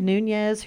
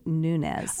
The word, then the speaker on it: Nunez: She pronounces it Nunez. – Oh, Nunez. It's not Nunez.